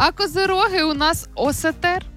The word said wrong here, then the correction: осетр: Осетр. – Осетр.